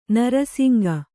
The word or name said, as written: ನರಸಿಂಗ